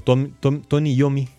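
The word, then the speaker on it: Tony Iommi: Y entonces resulta ser de que el líder y guitarrista de la, de la banda Black Sabbath, que se llama Tony Lomi, eh, perdón, Tony Yomi, ¿cómo me cuesta pronunciar dos y seguidas? Tommy Yomi, Tony Yomi, él notó que a pesar de que las películas eran de miedo, sí tenían siempre mucha audiencia.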